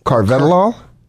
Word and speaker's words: Carvedilol: CARVEDILOL. 6 milligrams. – Looks like Carvedilol. – Carvedilol? – Carvedilol.